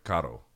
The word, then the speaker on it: carro: It's two R's. It's C-A-R-R-O. It's carro. Carro. Right? He can't say it. He kind of, like, flicks his tongue. He's more like carro. Carro. But he can't say carro. That's how you say it.